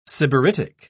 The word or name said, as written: sybaritic